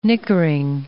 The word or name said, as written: nickering